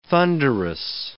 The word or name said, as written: thunderous